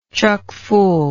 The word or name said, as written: chuckfull